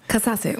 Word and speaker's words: Katasse: Der treue Bonus wartet immer noch bei uns und in der Zwischenzeit hat sich jemand gemeldet, hat uns aufgeklärt, dass es nicht Katasse ist, wie wir es ausgesprochen haben, aber wir waren uns da einfach nicht sicher. Haben wir nicht gewusst, wir haben wieder dazugelernt. Wird jetzt richtig ausgesprochen? – Katasse und ist natürlich die slowenische Bezeichnung von Edling.